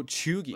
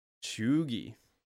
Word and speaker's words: cheugy: So, Chugi. – Like that. – Chugi. I did not know about this one. What is a Chugi? – Chugi. – How do you spell it? – C-H-E-U-G-Y.